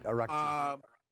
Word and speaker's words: Araghchi: And you had the Iranian foreign minister, Araghchi, I'm sorry for mispronouncing his name. – Right, Araghchi.